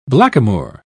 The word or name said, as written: blackamoor